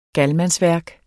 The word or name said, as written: galmandsværk